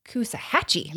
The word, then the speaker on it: Coosawhatchie: So the last one is C-O-O-S-A-W-H-A-T-C-H-I-E in South Carolina. I'm going to pronounce it. Kuza what? – So this is another silent letter. So just take out the W and you have it. – Kusahatchee.